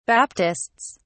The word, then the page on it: baptists: British English